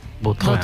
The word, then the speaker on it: Vautrin: La original francesa, Vautrin. – Vautrin.